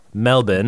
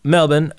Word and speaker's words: Melbourne: I've been to, and because I've been there, I have to now say it like they say it. Melbourne. – Danganronpa. – Danganronpa. Melbourne.